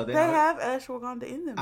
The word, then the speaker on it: ashwagandha: Yo, I started taking ashwagandha. Did I say it right this time? – Ashwagandha? – Ashwagandha. – Ashwagandha. Yes.